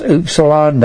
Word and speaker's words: upsilon: S-T-U, an upsilon. This is pronounced upsilon.